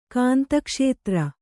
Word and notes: ಕಾಂತಕ್ಷೇತ್ರ: kāntakṣētra

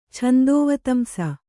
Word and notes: ಛಂದೋವತಂಸ: Kannada

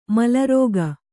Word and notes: ಮೇಲಾರೈಕೆ: kannada

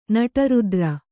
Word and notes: ನಡುರಾತ್ರೆ: naḍu rātre

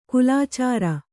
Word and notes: ಕೋಲಚಾರಿ: Kannada

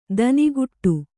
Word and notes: ದನಿಗೊಡು: Kannada

